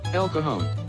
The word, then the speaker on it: El Cajon: El Cajon.